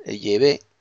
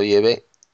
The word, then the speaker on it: Yahvé: Parece que Yahvé, pues parece ser que es la verdadera pronunciación del nombre hebreo, del Dios de Israel, Yahvé. En hebreo arcaico, pues parece ser que es Yahvé.